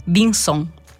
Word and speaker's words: Vinson: Infatti questo incontro sul volume L'Europa senza retorica di Barbara Curli si terrà a San Germano Chisone, non in via Vincon, ma via Vinson.